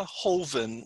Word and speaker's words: Joven: But the ones that I actively give to include something called Vita Hoven, which is spelled J-O-V-E-N. It's pronounced Hoven, though, because it's Spanish.